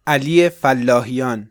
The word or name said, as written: Ali Fallahiyan